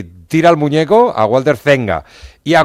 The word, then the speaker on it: Zenga: ¿Cómo se pronuncia Walter Zenga? – Zenga. – Ah, ¿no se pronuncia Seña? – No.